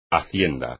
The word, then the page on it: hacienda: espagnol